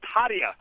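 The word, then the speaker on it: Haataja: You said Eric Tahaha. – Yep. – I think it's pronounced Hadia. – Hadia, it is. It's H-A-A-T-A-J-A. – Yeah.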